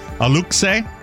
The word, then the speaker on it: Aluxe: He didn't seem to be joking when he posted a photo of the, I'll ask you on the pronunciation, A-L-U-X-E, Aluxe. – Yeah, Aluxe.